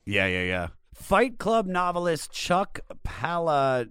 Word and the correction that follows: Palahniuk: Do you know how to say his name? – Polonia. – Okay. Fight Club novelist Chuck Palahniuk was a fan.